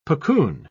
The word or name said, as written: puccoon